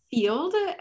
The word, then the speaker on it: Feeld: So this app is called Field, and I had to Google how to say it correctly. So F-E-E-L-D is the name.